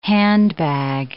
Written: handbag

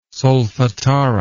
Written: solfatara

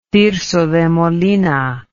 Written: Tirso de Molina

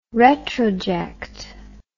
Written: retroject

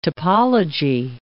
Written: topology

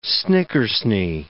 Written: snickersnee

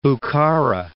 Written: Bukhara

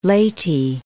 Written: Leyte

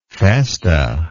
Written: festa

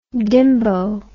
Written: dimbo

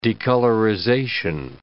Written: decolorization

